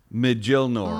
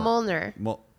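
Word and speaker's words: Mjölnir: Mjolnir. – Mjolnir. – Oh, cool. – I think the J is silent.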